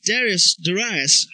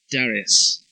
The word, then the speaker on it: Darius: Then the king Darius, however you want to pronounce it, my British roots say Darius. North America seems to want to say Darius when I've listened to it.